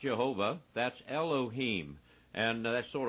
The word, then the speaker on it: Jehovah: you'll find out that it is Jehovah, J-H-V-H, with the vowels included. We pronounce it Jehovah.